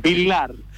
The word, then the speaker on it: Villar: Villar, porque es la pronunciación. Doppia L en italiano es Villar.